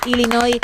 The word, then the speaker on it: Illinois: Así que bravo, sin pronunciar la S final, Illinois, una bravo, vez más, bravo, tu apunte de geografía que bueno.